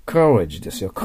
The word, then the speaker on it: courage: courage というのは、 college と間違わないようにね、 college は L の発音だから大学ですよ。 courage ですよ。